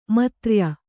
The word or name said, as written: ಮೈತ್ರೇಯ